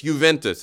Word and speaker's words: Juventus: It's Juventus. It's Italian.